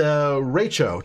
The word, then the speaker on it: derecho: It's a derecho. Derecho. I actually Googled how to pronounce it too. Derecho. It's the accent is on the middle syllable there.